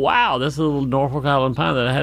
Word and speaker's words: Norfolk: I know that Norfolk sounds like Norfolk, Virginia, and it's spelled the same, but Norfolk Island is actually an island in the warm Pacific waters near Australia and New Zealand, I think.